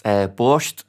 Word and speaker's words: borscht: So there's a meat and a vegan option, but it's based on, and I hope I'm pronouncing it right, borscht. Traditional Ukrainian dish. That sounded good. – Dish. It sounded good, mate.